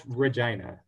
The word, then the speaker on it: Regina: Yes, it's pronounced Regina.